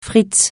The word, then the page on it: fritz: francais